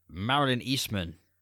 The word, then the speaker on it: Marilyn Eastman: A woman whose name I have here, Marilyn Eastman. – Oh, you pronounced that Marilyn. Stunningly well.